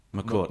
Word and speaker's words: McCourt: And so it's not the same as an Ineos at Nice, who are the owners and running it, or a Macor at Marseille, where this investment money, or QSI at Paris Saint-Germain. – We pronounce the T on McCourt. McCourt, McCourt, sorry, yes.